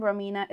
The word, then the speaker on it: Romina: Again, Romina is spelled R-O-M-I-N-A.